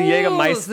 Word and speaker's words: Jägermeister: Or in Germany, how they say it? Jägermeister? – Oh, yes. – Because the Jägermeister...